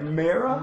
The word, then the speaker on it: Mera: A mirror. – No, his wife, whatever. M-E-R-A. – Oh, right. Okay. – Is that how you say it? Mirror? – Mirror.